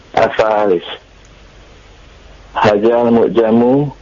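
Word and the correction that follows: Hadza (هذا): Hadzal mu'jamu. – Hajal mu'jamu bimi'ati. – Zalnya lebih perhalus lagi. Pengucapan zal. Hadza.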